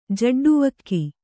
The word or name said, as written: ಜಡ್ಡುವಕ್ಕಿ